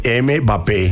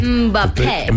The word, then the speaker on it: Mbappé: Como es Mbappé. – Mbappé. – Mbappé, exactamente.